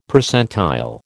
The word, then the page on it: Percentile: American English